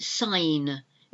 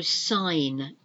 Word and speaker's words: sign: R-E-N-D-E-Z. V-O-U-S. Okay. And what have we got here? Nine. Nine. Sign. It's a G. It's the silent G. So sign. I'm going to sign the end of this document. S-I-G-N. Sign. And a signpost is something you see on the streets. And gnat. G-N-A-T is a nasty little insect that you might get gnat bites if you're unlucky.